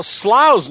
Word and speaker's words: Slough: Well, Slough's name is spelled S-L-O-U-G-H, subject to some mispronunciation, but from now on I'll just say Slough rhymes with wow.